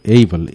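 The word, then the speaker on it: able: কিন্তু অনেকে অ্যাবেল বলে, অ্যাবেল না, উচ্চারণ হলো এইবল, A B L এইবল।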